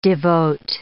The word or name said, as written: devote